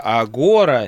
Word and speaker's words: Агора: И, слушай, я уточнял специально, где ставить ударение в его новой программе в названии. – Агора, агора или... – У меня, честно говоря, было два варианта. А тут три гласных, что ли, точно. В общем, программа называется «Агора».